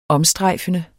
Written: omstrejfende

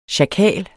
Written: sjakal

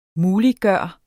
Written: MULIGGJORT

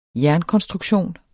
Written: jernkonstruktion

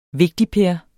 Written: vigtigper

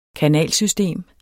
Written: kanalsystem

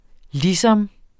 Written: ligesom

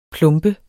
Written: plombe

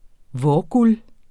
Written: vårguld

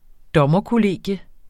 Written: dommerkollegium

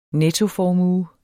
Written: nettoformue

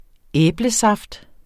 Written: æblesaft

